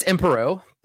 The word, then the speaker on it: Emperox: Is it Emperox or is it Emperor? – It's Emperor. The X is not pronounced.